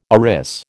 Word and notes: arris: American English

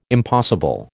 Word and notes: impossible: American English

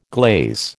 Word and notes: glaze: American English

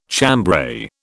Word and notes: chambray: British English

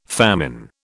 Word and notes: famine: British English

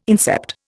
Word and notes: incept: Indian

